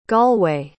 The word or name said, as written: galway